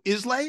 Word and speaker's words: Islay: Was Mike's recent trip to Islay? Is that how you say the Isla? It's pronounced Isla.